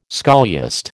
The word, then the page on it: scholiast: American English